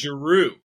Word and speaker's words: Giroud: Did you just say Giroud? – What? – Did you say Giroud? – Giroud. Olivier Giroud. – Giroud. French don't pronounce the last letter.